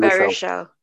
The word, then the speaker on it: Baruchel: Baruchel.